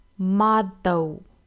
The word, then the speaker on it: model: 如果发成正经的 d 就变成了 model， model，但是我们在浊化成 d 的发音的时候呢，舌尖不能够抵住上齿龈的位置，而应该往后走，抵到硬腭的位置， d d model model，并且要发得非常的轻快，一带而过，不能够过于的拖沓啊，不是 model，而是 model model，轻轻的一弹就弹下来了。